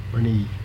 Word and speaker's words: Brnesee: Henry Bernice. – And what's your middle name? – Bernice. B-R-N-E-S-E-E, the way I spell it.